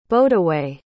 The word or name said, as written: bodaway